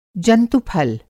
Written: जंतु-फल

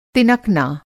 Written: तिनकना

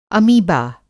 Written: अमीबा

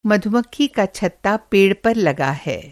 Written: मधुमक्खी का छत्ता

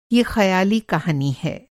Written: ख़याली

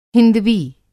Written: हिंदवी

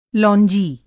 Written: लौंजी

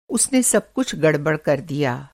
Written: गड़बड़

